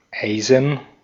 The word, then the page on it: Eisen: /ˈʔaɪ̯zn̩/; /ˈʔaɪ̯zən/; /ˈaɪ̯zn̩/; German